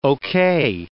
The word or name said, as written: OK